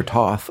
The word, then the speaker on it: Toth: Well, I'm a big Toth fan, or Toth. I always call him Toth. If I'm wrong, sue me. – T-O-T-H. – It's Toth. – Rhymes with sloth.